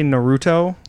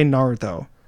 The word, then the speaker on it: Naruto: They say Naruto. – Naruto? – And it's not how you say it. If you listen to the show, they say Naruto. – Naruto. – Literally listen to anyone say it Naruto.